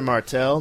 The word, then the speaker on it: Martel: You can follow me at Mr. Martel. That's Mr. spelled out. And Martel, M-A-R-T-E-L, with a little underscore in between.